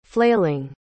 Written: flailing

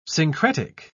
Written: syncretic

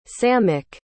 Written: samek

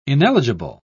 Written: ineligible

